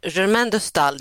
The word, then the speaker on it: Germaine de Staël: Ja, alltså Germaine de Staël, det är inte lätt att uttala, men så ska det uttalas.